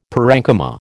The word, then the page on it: parenchyma: American English